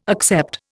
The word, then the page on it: accept: Indian